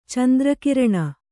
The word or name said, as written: ಚಂದ್ರಕಿರಣ